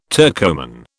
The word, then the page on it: Turcoman: British English